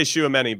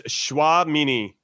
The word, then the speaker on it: Tchouaméni: Um, and then and then uh, I used to say Shuamini, but then I learned how to pronounce it properly. – Since is it not Chomini? Uh, Chomini? – No, it's it's Tchouaméni. Tchouaméni. Yeah.